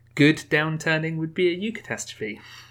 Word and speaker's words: eucatastrophe: Is that how you say it? You're the resident pronunciation expert. – Yeah, that's fine. Yeah, eucatastrophe. So that's from the Greek, obviously, eu meaning well, and like eugenics, getting your genes done correctly.